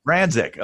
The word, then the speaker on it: Franczyk: We both spoke at an event in Toronto recently, and Sara, Franczyk, am I pronouncing it correctly? – Yeah, that's actually pretty good. Franczyk, yeah. – Franczyk, okay.